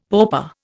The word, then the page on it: pauper: Indian